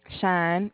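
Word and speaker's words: Shyne: And it's pronounced shiny? – Shine, just shine.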